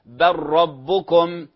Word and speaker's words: بل ربكم: وكذلك لام بل، بل في الراء، بل في كلمة بل وكلمة ربكم، فنقول بل ربكم بل ربكم، هذا إدغام. يستثنى